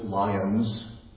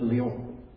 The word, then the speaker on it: Lyon: So instead of pronouncing it the way we would pronounce this, lions, they pronounce it Lyon, like someone suddenly in the middle of the word.